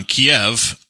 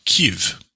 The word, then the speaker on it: Kyiv: Like, we were raised on Kiev, and then since it has, they've gone independent, now they call it Kyiv.